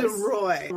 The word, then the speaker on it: Duroy: Did I say that right? DeRoy. – Close. Duroy. Roy.